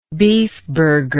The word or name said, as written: beefburger